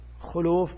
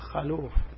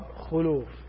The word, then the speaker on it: خلوف: ثم بين عليه الصلاة والسلام وأقسم على هذا الأمر تأكيدا، والذي نفس محمد بيده عليه الصلاة والسلام لخلفة، وفي اللفظ الآخر لخلوف، خلوف بضم الخاف بضم الخاء واللام، بعض الناس ينطقها بفتح الخاء وهذا خطأ، يقول خلوف، لا الصواب خلوف،